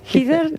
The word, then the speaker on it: Heather: Yo digo Heather Lockhart, ¿no? – Heather. Heather se dice. Sí. Toda la vida Heather.